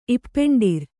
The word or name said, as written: ಇಪ್ಪೆಂಡಿರ್